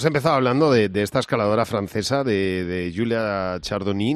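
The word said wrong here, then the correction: Chanourdie: no sé si lo digo bien o no, pero que es una... ¿Cómo se dice? – Julia Chanurdi. – Chanurdi, bueno, pues esta mujer que es un animal,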